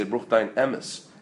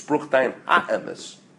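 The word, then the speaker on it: Bruch Dayan HaEmes (ברוך דיין האמת): Rachmanu Litzlan, if you ever hear someone pass away, people a lot of times make a mistake, they say Bruch Dayan Emes. It's Bruch Dayan HaEmes.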